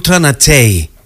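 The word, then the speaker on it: Ultra Naté: Θέλω να διορθώσω λίγο την προφορά του ονόματος της σπουδαίας αυτής old school καλλιτέχνηδας, θα λέγαμε, γιατί είναι η Ultra Natei. Έτσι προφέρεται το όνομά της και την ξέρετε ξεκάθαρα από αυτό εδώ το τραγούδι, το οποίο είναι καθαρά ήχος της δεκαετίας του 90.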